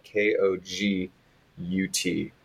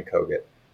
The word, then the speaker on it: Kogut: My last name is spelled K O G U T, Ben Kogut.